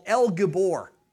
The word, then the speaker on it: El Gibor: The word is in Hebrew one that we can actually pronounce. It's called El Gibor.